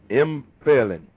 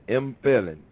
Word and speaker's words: empfehlen: Empfehlen. Like the P is silent though. Empfehlen. Like how you doing in school? I'm failing, but instead of I'm, it's M.